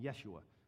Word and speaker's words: Yeshua: In his times, they would have called him Yeshua, which is how Hebrews pronounce the name Joshua.